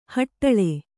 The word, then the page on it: ಹಟ್ಟಳೆ: haṭṭaḷe